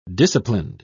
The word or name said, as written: disciplined